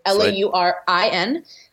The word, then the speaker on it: Laurin: So it's Laurin. All my social media is at Laurin Conlin.